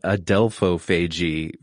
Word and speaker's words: adelphophagy: if pronouncing this wrong, so it's sand tiger sharks in particular, adelphophagy? – That's right, yeah.